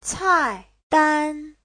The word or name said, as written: cai4dan1